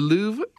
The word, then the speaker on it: Louvre: The Louvre. I, I say it right? the big one in France where Mona Lisa is.